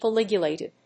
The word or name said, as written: polyglutamate